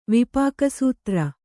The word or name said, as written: ವಿಪಾಕಸೂತ್ರ